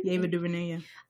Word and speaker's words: Ava DuVernay: Ava DuVernay, yeah.